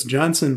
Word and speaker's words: Jonzun: And then a band called Johnson Crew, which I had never heard of, but that's Johnson, but it's spelled J-O-N-Z-U-N.